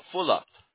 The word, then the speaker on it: Fulop: Stephen Muller? – No, Fulop. Stephen Fulop. – Stephen Fuller? I don't know who they are. – No, it's F-U-L-O-P.